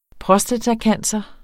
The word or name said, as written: prostatacancer